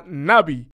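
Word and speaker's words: Nabi (נביא): Si miramos la palabra hebrea, la palabra se pronuncia Nabi.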